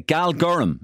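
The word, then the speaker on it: Galgorm: Okay, Galgorum, is that the pronunciation? – Yeah, that's right. It's just outside Belfast.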